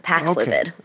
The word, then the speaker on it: Paxlovid: Did I say that right? Paxlovid, is that correct? – That's right, yeah. Paxlovid.